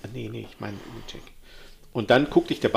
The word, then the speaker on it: Ledaig: Und da gibt es eben den Ledaig, also geschrieben L-E-D-A-I-G. Und ich würde ihn ins Englisch aussprechen, Ledaig, aber er heißt Lechek.